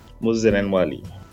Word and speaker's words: Moses Enenwali: Moses Enenwali.